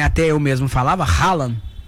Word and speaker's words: Haaland: Rolam.